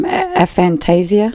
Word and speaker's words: aphantasia: So she mentioned she has, am I saying this right, Jim? Aphantasia? – Yes.